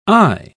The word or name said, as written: aye